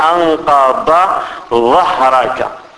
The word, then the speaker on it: أنقض ظهرك: وعيد النطق لأنه فيها الضاد وفيها الظاء. الذي أنقض ظهرك. لأن يعني حتى في البلدان, هذه بلدان المغرب العربي وكذا, يعني الأئمة دائما يحذرون ممن لا ينطق هاتين الكلمتين نطقا سليما, فتجده يقول أنقض ظهرك هكذا, لا حرفين يعني متقاربين من حيث النطق, يجب التفريق بينهما. نقول أنقض ظهرك, ضاد وظاء.